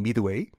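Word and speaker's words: Midway: 미ッ웨이영어발음은 미드웨이. 미드웨이 데스.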